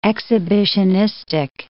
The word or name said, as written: exhibitionistic